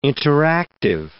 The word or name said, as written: interactive